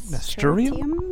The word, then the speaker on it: nasturtium: How do you say that? – Nasturtium? – Yeah.